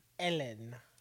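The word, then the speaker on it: Hélène: Think of like Helen, but like with the H is silent. – Ellen.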